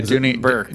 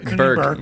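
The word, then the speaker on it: Dooney & Bourke: a Dooney, a, a, a, nice Dooney and Bork uh, handbag, cruise line. Am I saying it wrong? – Yeah, Dooney Bourke. Burke.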